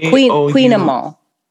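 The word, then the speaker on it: kouign-amann: I wrote queen as in Q-U-E-E-N, but it's actually K-O-U-I-G-N. I don't know. Queen Emma. And it's a Breton pastry.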